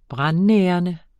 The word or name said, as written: brandnærende